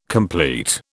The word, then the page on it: compleat: British English